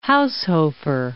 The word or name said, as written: Haushofer